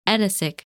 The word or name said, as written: Edasich